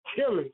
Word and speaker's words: Tillis: It began at Tillis. – Yep. That's him. – I say it right? – Yep. Sure did.